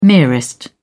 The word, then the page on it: merest: British English